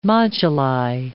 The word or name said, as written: moduli